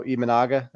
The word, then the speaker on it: Imanaga: And I know the guy tomorrow, Imanaga, that's how you pronounce it.